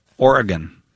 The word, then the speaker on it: Oregon: Kevin in Portland, Oregon. That's the correct pronunciation, you know. – Oregon, yes. Not Oregon.